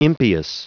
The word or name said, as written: impious